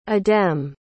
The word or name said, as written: adem